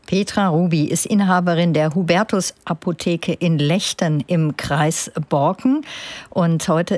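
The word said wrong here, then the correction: Legden: Und seit mich empörte Rückmeldungen aus meinem Kollegenkreis erreicht haben, wie ich den Ort ausgesprochen habe, weiß ich auch, dass es Lechten heißt, im Kreis Borken.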